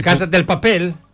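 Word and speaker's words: Casa de Papel: Μιλάω για την τέλεια ληστεία, το Casa de la Papel. – Έτσι, Casa del Papel. – Casa del Papel. – Casa del Papel. Χωρίς το ρούδο δεν προφέρεται. – De Papel.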